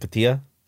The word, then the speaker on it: Cotija: C-O-T-I-J-A. – C-O-T-I... Cotija? – Cotija? Cotija? – Cotija. – Cotija. But there's no Cotija. There's no, like, accent, so it's like Cotija. – Cotija, maybe? – Cotija. – Well, Cotija.